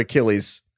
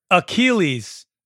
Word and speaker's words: Achilles: Well, you're saying it like a kill, like K-I-L-L is like how I say it, like Achilles.